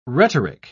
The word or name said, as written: rhetoric